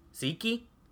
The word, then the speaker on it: tzatziki: Tzatziki. – Tzatziki. – Tzatziki. – Tzatziki. You start with a T sound.